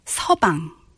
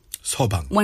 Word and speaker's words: 서방: Son-in-law here, well, not the literal translation, but in Korea, usually, wife's parents would call son-in-law 서방. – 서방. – One's husband. – Okay. – So, if you're Kim, you're Kim 서방.